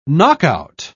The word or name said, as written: knockout